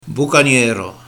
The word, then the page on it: Bocanhera: Boucagnéro